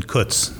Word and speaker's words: Kutz: My name is Brandon Kutz. Rhymes with foots, toots, and puts.